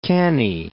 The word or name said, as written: Cannae